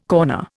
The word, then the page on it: corner: Indian